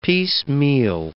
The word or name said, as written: piecemeal